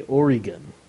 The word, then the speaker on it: Oregon: I should actually say Oregon.